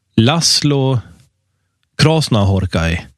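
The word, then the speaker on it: László Krasznahorkai: En ungrare som heter Laszlo Krasznahorkai. – Ja. – Det blir mitt bidrag till den här diskussionen. Ett korrekt uttal av hans ungerska namn.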